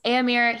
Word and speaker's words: Éomer: So when they first have Éomer, is that how you say it? – Mm-hmm. – Yes. Éomer, he finds Theodred.